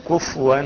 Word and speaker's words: كفوا: بعض الإخوة ينطقون كفوا بتسكين الفاء، والصواب ضمها كفوا، أي نعم تأخذ بالك، ولم يكن له كفوا، كفوا مش كفوا، ليس بتسكين الفاء ولكن بضم الإيه؟ ضم.